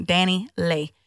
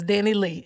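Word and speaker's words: Dani Leigh: Danny Lay. Um, oh, that's how you say it. I thought it was Danny Lee.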